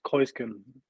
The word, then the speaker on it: Keusgen: The E and U in the German language, I don't know why, for whatever reason, is pronounced oi. So that's why usually you get like Keusgen, but it's like Keusgen.